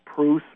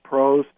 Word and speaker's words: Proos: or John Prose, P-R-O-O-S. It looks like Proust, but it's pronounced Prose.